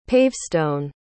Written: pavestone

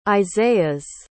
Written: isaias

